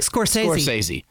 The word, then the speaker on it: Scorsese: Scorsese. – Scorsese. Martin Scorsese. – Okay. – Quentin Tarantino. – Okay. – Or Steven Spielberg. – Huh. Well, I'm going to do the process of elimination here. Okay. Scorsese, I think, is from New York.